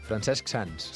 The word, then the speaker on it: Francesc Sans: És difícil de pronunciar. – Francesc Sans. – Sí, perquè has d'acabar la Q i començar la...